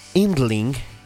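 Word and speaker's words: Yndling: Y el proyecto se llama Indling. Indling, que pues está en danés de hecho. Indling, que se escribe con Y al principio, con Y.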